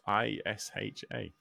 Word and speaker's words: Isha: Uh, it is spelled I S H A.